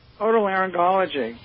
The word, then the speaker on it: otolaryngology: Otolaryngology.